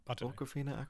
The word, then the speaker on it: Awkwafina: Awkwafina, Awkwafina.